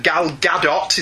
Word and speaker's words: Gal Gadot: The Warrior Wonder Woman of the New 52, And the version that Gal Gadot, is that how you pronounce her name? – Yeah.